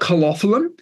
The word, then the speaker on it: calophyllum: So then the calophyllum, is that how you pronounce it? – Oh, look, I call it calophyllum.